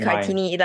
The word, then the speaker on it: Kartini: And but my name is Kartini.